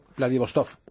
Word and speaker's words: Vladivostok: No sé si lo he dicho bien, Vladivostok. – Perfecto. Vladivostok.